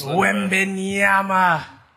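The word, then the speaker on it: Wembanyama: Well, I tell you what's Wembenyama. That's how you say it in 2K.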